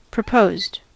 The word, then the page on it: proposed: American English